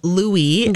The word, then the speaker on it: Louis: Formally announce to everybody that it is Louis, not Louis. That is the pronunciation according to People magazine. They have a whole headline, Louis or Louis. Here's how to pronounce the royal baby's name. Yes, Americanized pronunciation is Louis. The French pronunciation is Louis, and the answer is the latter.